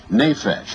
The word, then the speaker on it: nephesh: It's a word that maybe we pronounce it improperly, but it's called nephesh, N-E-P-H-E-S-H, in the English letters.